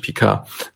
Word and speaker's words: Picard: Gut, vielleicht hat, hat Shaw sich mit Picard und den, den Logbüchern, ich muss mich mal darauf einigen, wie ich den jetzt ausspreche, ausspreche, äh, einigen wir uns auf das deutsche Picard.